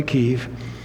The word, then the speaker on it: Kyiv: By the way, it's Kyiv or Kiev. Either way is okay. Kyiv is the Ukrainian pronunciation. Kiev is Russian pronunciation. Either works.